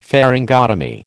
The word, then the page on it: pharyngotomy: American English